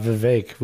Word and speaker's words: Vivek: Anytime we talk about Vivek, we always can play. – Rhymes with cake.